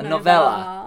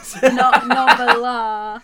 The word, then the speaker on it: novella: It's a novella. – A novella. A novella. – Novella. – Is that how it's pronounced? I've only ever seen that word written down. – Noveler. – I've always pronounced it noveler.